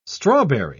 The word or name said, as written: strawberry